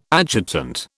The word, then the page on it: adjutant: British English